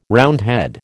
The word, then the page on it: Roundhead: American English